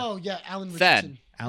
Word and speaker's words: Ritch: Alan Rich, how do you pronounce his last name? Alan Richardson? – Richardson? – I say Richardson. – It's Richardson. – Is it Ritson? From what? – R-I-T-C-H.